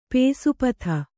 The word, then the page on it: ಪೇಸುಪತ: pēsu pata